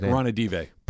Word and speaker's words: Vivek Ranadive: Vivek Ranadive.